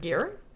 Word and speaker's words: Gere: Is that right? Gere? – Richard Gere? – Is that how you pronounce that? – That's how I've always pronounced his last name.